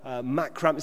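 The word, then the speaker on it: macramé: macrame. Is that how you say it? I've never heard of that word before. Macrame mojitos, have I said that right? Yeah, well, people are nodding.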